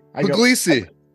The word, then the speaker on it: Pugliese: Close enough. Pugliese. Pugliese. – Pugliese. – I go by all of it. None of it offends.